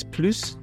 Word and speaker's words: plus: So when you pronounce the S, plus, more, plus, less.